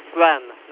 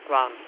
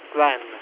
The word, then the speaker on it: Gwen: By the way, you mispronounced my name, it's Gren, not Gran, Gren, G-W-E-N.